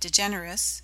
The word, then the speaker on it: DeGeneres: But DeGeneres has four syllables.